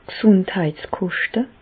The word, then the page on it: d' Gesùndheitskoschte: Bas-Rhin; Reichshoffen